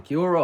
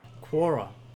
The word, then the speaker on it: Quora: How do you even pronounce it? Kiora or Quora? Quora. See, right, yeah.